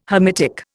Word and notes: Hamitic: Indian

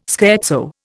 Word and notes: scherzo: Indian

